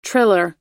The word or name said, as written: triller